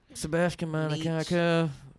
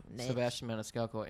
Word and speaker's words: Sebastian Maniscalco: Sebastian Maniscalco. Sebastian Maniscalco.